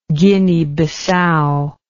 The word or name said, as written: Guinea-Bissau